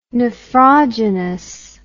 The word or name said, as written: nephrogenous